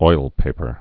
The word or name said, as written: oilpaper's